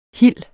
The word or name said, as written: hil